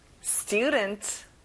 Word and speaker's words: student: 학생, student. student, stu에 강세를 넣어보세요. 교실, classroom, class에 강세를 넣어보면 좋아요.